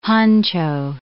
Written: honcho

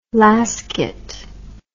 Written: lasket

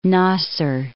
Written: Nasser

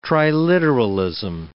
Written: triliteralism

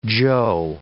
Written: Chou

